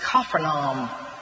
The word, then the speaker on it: Capernaum: Where he was in a fishing village called Capernaum, which it really in Aramaic would have been pronounced Capernaum.